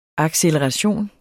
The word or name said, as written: acceleration